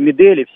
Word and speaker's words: Медель: Вот разбирая тот момент, там, конечно, объективности ради стоит сказать о том, что вот когда они сблизились, Медель все-таки Медель, ударение, спасибо.